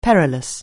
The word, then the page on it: perilous: anglais